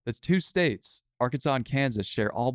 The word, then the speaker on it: Kansas: It is the English spelling, though, so naturally you pronounce the final S. So if France had nothing to do with this, it would be Arkansas and Kansas.